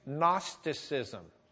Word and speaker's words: Gnosticism: It's Gnosticism. The G is silent.